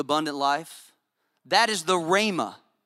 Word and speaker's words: rhema: There's another word that's translated word called, it's pronounced rhema. Everybody say rhema.